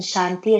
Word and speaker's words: Shanti: For example, Shanti, there's two T's, there's ta and ta, and Shanti is ta.